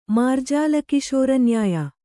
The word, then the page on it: ಮಾರ್ಜಾಲಕಿಶೋರನ್ಯಾಯ: mārjāla kiśora nyāya